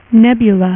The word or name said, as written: Nebula